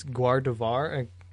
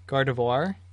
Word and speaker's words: Gardevoir: So I always butcher how to pronounce this Pokemon, but it's Gardevoir. Gardevoir.